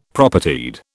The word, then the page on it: Propertied: British English